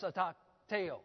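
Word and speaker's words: a takteo (ἀτακτέω): Idleness, or the word idle, as a verb, it's a takteo. Phonetically, it's spelled A-T-A-K-T-E-O, a takteo.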